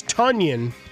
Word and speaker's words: Tonyan: And our guy from a couple of weeks ago, as Vince pointed out, it is Tonyan. He's questionable with an ankle injury. – That rhymes with Funyan.